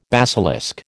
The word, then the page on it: basilisk: American English